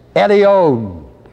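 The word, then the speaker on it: Elyon: And finally, the 10th usage in the word is Jehovah Eliyon, spelled E-L-Y, long O, Eliyon, N, E-L-Y, long O, N.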